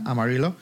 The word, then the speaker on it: Amarillo: Lógicamente la pronunciación en castellano se ha perdido, como os contaba, ahora se pronuncia Amarillo.